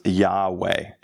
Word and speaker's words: Yahweh: Many believe that the correct way to say it is Yahweh.